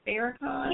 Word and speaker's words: Farrakhan: Did you see, I may be wrong, how do you say his name? Minister Farrakhan? – Yeah, that's right.